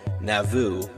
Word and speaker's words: Nauvoo: He was the mayor of a city in Indiana called Nauvoo, which is spelled N-A-U-V-O-O, which is quite similar to the sound of the planet name Naboo in Star Wars, N-A-B-O-O.